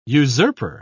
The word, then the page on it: usurper: British English